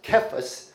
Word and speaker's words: Cephas: This is in Aramaic, and it's pronounced Cephas, and it means rock. In Greek, it's Petros.